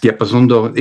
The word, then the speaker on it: Diapason d'Or: The award, is it Diapason d'Or that you won? – Yes, Diapason d'Or is the foremost French award.